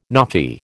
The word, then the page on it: NAAFI: American English